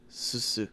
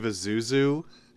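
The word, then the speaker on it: Zissou: Of the Life Aquatic with Steve Susu. – So for the longest time, I thought this was Steve Azuzu. Like, and I put too many syllables in it, and I would always say it like Azuzu.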